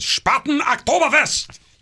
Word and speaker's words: Spaten Oktoberfest: Well, the Spaten Oktoberfest, if you say it, I got to say it like the Germans, Spaten Oktoberfest!